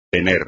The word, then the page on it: tener: espagnol